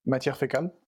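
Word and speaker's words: Matière Fécale: How do you say their Instagram handle? – Matière Fecale. – Matière Fecale. So a nicer French accent.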